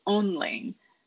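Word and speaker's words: Önling: I knit mine using a new-to-me yarn company from Denmark called Onling. It's spelled O with an umlaut, N-L-I-N-G.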